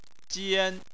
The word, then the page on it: jian: 1